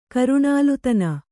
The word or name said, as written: ಕರುಣಾಲುತನ